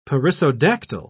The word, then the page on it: perissodactyl: British English